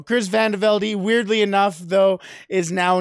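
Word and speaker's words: VandeVelde: And the thing about Chris Van Velde is yes, it's pronounced Van Velde. He wanted to make that very clear from the start,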